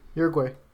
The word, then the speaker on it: Uruguay: And from Miami, she goes to Uruguay. Did I say that right? Uruguay? – Sure. Yeah, Uruguay.